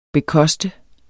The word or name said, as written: bekoste